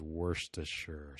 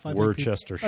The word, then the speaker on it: Worcestershire: And something that I've really been getting into to help things out, sauces and that sort of thing, is Worcestershire sauce. – Yeah. – Right. Did I say that right? – Close enough. – All you English folks out there. – Close enough. – Worcestershire.